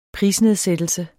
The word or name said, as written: prisnedsættelse